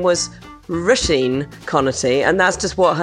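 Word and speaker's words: Róisín: So the correct way to pronounce her name is Roisin, like row your boat and Mr. Sheen. Roisin.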